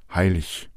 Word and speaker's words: heilig: Die EG-Regel besagt, dass ein EG am Ende eines Wortes oder am Ende einer Silbe als ICH gesprochen wird. Das heißt, es heißt König, innig, heilig.